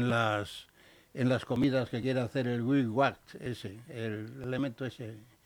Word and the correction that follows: Bill Gates: Bill, Gates, Bill Gates como se mm, pronuncia, dijo, es que quiere hacer comidas.